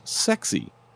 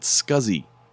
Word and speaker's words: SCSI: The creator, Larry Boucher, or is that Boucher, intended for it to be pronounced sexy. But nearly everyone saw SCSI and pronounced it scuzzy.